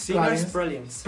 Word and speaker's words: Sinners Pralines: Sì, come si pronuncia questo titolo? Sinners Pralines? – Sinners Pralines. – Pralines.